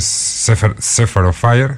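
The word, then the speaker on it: Shepherd of Fire: Eh, la canción es Sefer, of Fire, si lo estoy pronunciando bien. – Toma ya, sí, sí, perfecto, perfecto.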